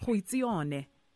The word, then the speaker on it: Goitseone: Goitseone.